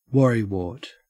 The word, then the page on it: worrywart: Australian English